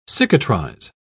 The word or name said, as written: cicatrize